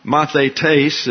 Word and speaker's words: mathetes: And mathetes is the way it's pronounced.